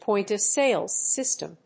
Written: point of sales system

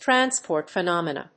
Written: transport phenomena

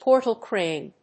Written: portal crane

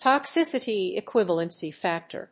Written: toxicity equivalency factor

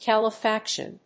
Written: calefaction

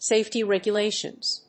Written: safety regulations

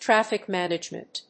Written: traffic management

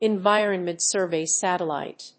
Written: environment survey satellite

